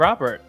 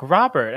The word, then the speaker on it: Robert: I'm Robert. I always say it like weird, like Robert. And I don't say it like that.